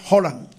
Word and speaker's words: Haaland: Yo escuché hoy a un amigo dominicano que estudió en Noruega y dijo que ese apellido se pronuncia Haaland.